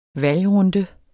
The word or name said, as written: valgrunde